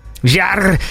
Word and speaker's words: Jarre: How do you say that guy's name? Jean-Michel Jarre. It's Jarre. – If you pronounce it in French, I suppose it'd be Jean-Michel Jarre. – Jarre.